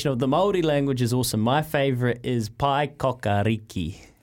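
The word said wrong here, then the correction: Paekākāriki: Pai Kakariki.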